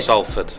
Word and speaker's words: Salford: Salford.